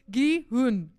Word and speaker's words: Gi-hun: Alternate theory. Gihan. I didn't say his name right. Alternate theory. Now I'm going to stop everything. Gi-hun.